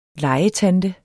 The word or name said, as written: LEGETANTE